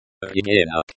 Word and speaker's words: Ребенок: Ребенок. Ребенок. The stress is on the second syllable, and the O sounds like the O in hot.